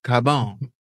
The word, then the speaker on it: Carbone: See, you got to say it like you're Italian. Carbone.